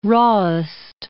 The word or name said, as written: rawest